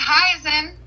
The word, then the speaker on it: Hizon: It's Hizon.